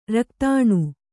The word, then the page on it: ರಕ್ತಾಣು: raktāṇu